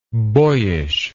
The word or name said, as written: boyishness